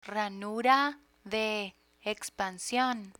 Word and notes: ranura de expansion: Spanish

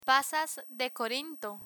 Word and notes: pasas de corinto: Spanish

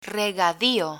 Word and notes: regadio: spanish

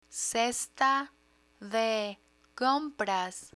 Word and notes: cesta de compras: spanish

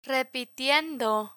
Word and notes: repitiendo: spanish

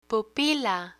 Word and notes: pupila: Spanish

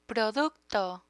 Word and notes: producto: Spanish

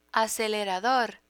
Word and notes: acelerador: Spanish

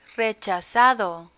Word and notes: rechazado: Spanish

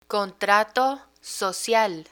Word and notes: contrato social: Spanish

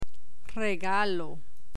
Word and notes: regalo: Spanish

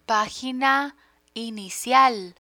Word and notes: pagina inicial: Spanish

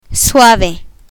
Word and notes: suave: Spanish

